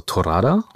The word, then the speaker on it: Torrada: Und in Katalonien heißt Barbecue übrigens auch Torada. – Sehr schön ausgesprochen.